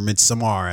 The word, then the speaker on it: Midsommar: Yeah, or Midsommar, as apparently it's supposed to be pronounced.